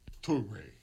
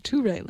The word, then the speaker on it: Touré: I said it wrong. – Touré. – I was fixing it. Touré, like the number two, is how it's supposed to be properly said.